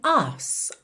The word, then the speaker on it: arse: É, essa expressão, to dance your ass off, ass aqui é uma palavra do inglês britânico, que é A-R-S-E. Como eles não costumam enrolar o R, você não tem tanto aquela pronúncia ars, ars, e sim us, us, ok?